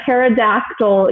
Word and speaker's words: pterodactyl: Why can't you hear a pterodactyl use the bathroom? – I have no idea. – Because the P is silent.